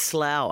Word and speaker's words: Slough: Or Slow. Is that how I should pronounce it? – No, I think it's Slough. I think it's Slough, yep.